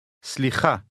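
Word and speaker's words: Slicha (סליחה): The Hebrew speaker is going to repeat this word part by part, starting from the end. You are to repeat each part after him, trying to make your pronunciation sound exactly like his. Be sure to repeat aloud. – Ha. Ha. Sli. Sli. Slicha. Slicha.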